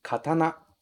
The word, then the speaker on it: katana: A gente também não nasaliza muito esse A. Em português a gente fala katana, com esse an aqui, katana.